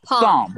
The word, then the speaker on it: Psalm: Oh yeah, it's pronounced palm. Psalm. Palm. – Yeah. – Like Palm Sunday.